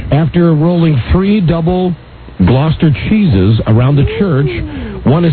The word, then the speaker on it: Gloucester: From Gloucester, is that how you pronounce it? Yep, Gloucester. That's what I hear.